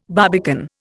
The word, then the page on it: barbican: Indian